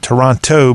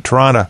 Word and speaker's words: Toronto: You pronounce Toronto, if you're a true Canadian, you pronounce it like it's T-R-O-N-O-D-O instead of T-O at the end. Not Toronto, but Toronto.